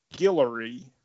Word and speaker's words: Guillory: And drawn and colored by Rob Guillory. I think that's how you say his name. – Yep, that sounds right.